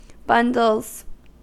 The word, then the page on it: bundles: American English